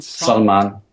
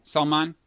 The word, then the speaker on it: Salman: How does he pronounce the last? It's bin Salman. – Salman.